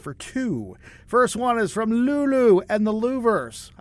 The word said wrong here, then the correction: Lovers: Did I do that correctly? It's the Lovers, not the Louvers, the way it's spelled. It's Lulu and the Lovers.